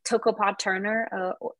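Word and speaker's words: Toko-pa Turner: And so there's like all these like tensions I feel like you have to hold, and without getting too existential, I think Tokopa Turner, or is it, I don't know how to say her name correctly. – I think that's right.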